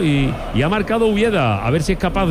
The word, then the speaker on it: Ueda: Ulleda, o Ueda se escribe, le llaman Ulleda, Ueda.